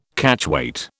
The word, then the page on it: Catchweight: British English